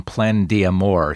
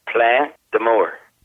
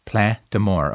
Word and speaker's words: Plein d'Amour: Gary, thank you very much for joining me and congratulations on the new album, Plein d'Amour. Say that I got close to the correct pronunciation. – Well, the first part is good, but the pronunciation is Plein d'Amour. – Plein d'Amour.